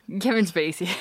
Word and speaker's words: Kevin Spacey: Kevin Spacey.